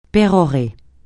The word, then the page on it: pérorer: francais